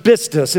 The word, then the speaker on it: pistis: It comes from a Greek word called pronounced pistis. It's spelled P-I-S-T-I-S, but it's pronounced with a B, pistis.